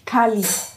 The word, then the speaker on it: Cali: Cali. – Einfach so, wie man es eigentlich schreiben kann.